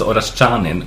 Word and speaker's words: Oraskanin: Nikola Oraskanin. – Uttalas det Oraskanin? – Nej, det gör det inte. Du uttalas Oraskanin, men tyvärr kan ju ingen svensk uttala det korrekt.